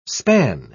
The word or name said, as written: span